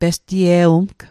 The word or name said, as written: pestie'wumg